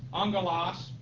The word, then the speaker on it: Angelos: A-N-G-E-L-O-S. Again, it's actually two gammas in the Greek, but under the Greek rules of pronunciation, when you have two hard consonants like that, the first one is pronounced as a nu, an N.